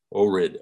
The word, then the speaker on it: Ohrid: Ohrid, O H R I D. Is that correct? – Yeah, I always pronounce it Ohrid, but okay.